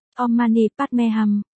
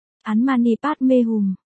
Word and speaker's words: Om Mani Padme Hum: Theo tiếng Phạn là, Om Mani Padme Hum, có vài cách đọc theo tiếng Việt là, An Mani Padme Hum, An Mani Bát Si Hồng, An Mani Pết Nạp Minh Hồng.